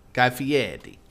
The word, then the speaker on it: Guy Fieri: How do you say his name? – Guy Fieri. – Yeah, there you go. Guy Fieri. – That's how he wants you to say it.